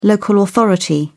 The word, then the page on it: local authority: British English